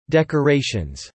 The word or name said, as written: decorations